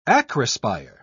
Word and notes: acrospire: British English

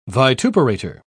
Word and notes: vituperator: British English

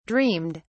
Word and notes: dreamed: British English

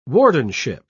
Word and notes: wardenship: British English

